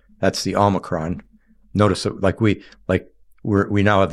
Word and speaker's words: Omicron: Well, Omicron is O micron, like it's a micro O is Omicron, and the Omega is the mega O, the last, and it's a long O.